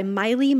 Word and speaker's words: Maile: All right, my last one this week is Do Not Become Alarmed by Miley Malloy. Miley is spelled M-A-I-L-E if you go Googling.